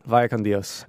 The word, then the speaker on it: Vaya con Dios: How would you say it? – Vaya con Dios.